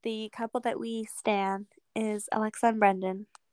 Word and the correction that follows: Brennan: Is it Brendan? – Yes, we love you. – Did I just say his name wrong? Wait, what is it? Brennan? Brennan. – It's Brennan.